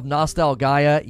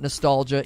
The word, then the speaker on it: Nostalgaea: The Last Hero of Nostalgaea. Yes, it's pronounced that way, not nostalgia.